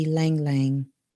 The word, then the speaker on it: ylang: One of the essential oils that I would recommend is going to be Lang Lang. You know, it's spelled Y-L-A-N-G.